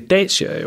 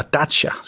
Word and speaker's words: Dacia: Og apropos børnecontainer, så har rumænske Dacia jo, eller Dacia, sådan der udtales det vist,